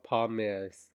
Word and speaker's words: palmier: For the showstopper, the bakers are tasked with making 6 Napoleons with pastry cream and 12 palmiers. How do you pronounce that? – It's French, so palmiers. You know we're experts. – Palmiers? – I think it's palmier. – Palmier? – Yeah. – So 3 syllable.